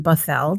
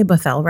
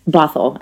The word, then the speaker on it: Bothell: Bothell. Did I say Bothell right? – Bothell, actually.